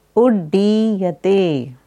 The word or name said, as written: Uddiyate